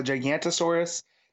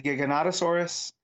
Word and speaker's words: Giganotosaurus: Then I heard a paleontologist on a podcast. It was a Giganotosaurus. Or Giganotosaurus, depending on how you want to say it. And I was like, I've been saying this wrong for a very long time.